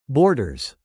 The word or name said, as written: borders